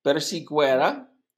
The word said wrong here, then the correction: persiguiera: No, no es persiguera, es persiguiera. – Oh. – Porque la G-U-I tiene pronunciación de gui. Persiguiera.